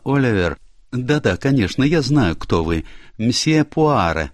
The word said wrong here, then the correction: Пуаро: Пуаро, мадам, — поправил ее он, сделав ударение на последний гласный.